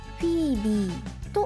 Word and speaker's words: FEBE: アルファベット FEBE と書いてフィービーと読みます。